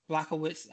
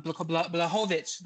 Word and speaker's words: Błachowicz: Błachowicz, Jan Błachowicz is how you say his name.